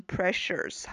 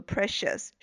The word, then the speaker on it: precious: 但是呢，读的时候一定要注意后边的这个 shes 啊，它是没有 r 的，所以不能卷舌读成 precious 哈，应该读作 precious, 舌头是直的。